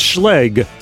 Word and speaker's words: Schlage: It's Schlage with a Schlage. – Hard G.